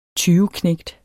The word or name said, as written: tyveknægt